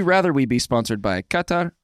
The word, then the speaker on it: Qatar: Qatar. Thank you, for the pronunciation. Make sure we got it right.